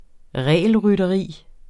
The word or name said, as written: regelrytteri